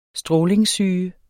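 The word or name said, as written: strålingssyge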